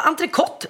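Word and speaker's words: entrecôte: Ja, entrecôt ska det faktiskt uttalas. – Det ska vara t på slutet där.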